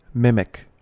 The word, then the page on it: mimic: American English